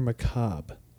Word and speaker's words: macabre: Is it macabre or macabre? The R-E is silent. – I believe it is macabre.